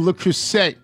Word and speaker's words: Le Creuset: you are pronouncing Le Creuset incorrectly. Le rhymes with duh, le, and Creuset rhymes with hey, as in hey, I'm a dope. You are also pronouncing Thule wrong, T-H-U-L-E, is it Thule or Thule?